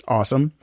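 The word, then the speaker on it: Ossum: That's O-S-S-U-M. So it like kind of looks like possum, but it's pronounced awesome.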